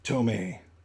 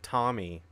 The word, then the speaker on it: Tomy: Tomy. – I always pronounce that Tommy, but, dude, those look like...